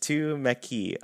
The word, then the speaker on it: Tumeke: Tumeke, pronounced Tumeki.